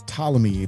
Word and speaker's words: Ptolemy: Yeah, that's Ptolemy. It's no, there's the P is silent, sir.